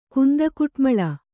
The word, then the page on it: ಕುಂದಕುಟ್ಮಳ: Kannada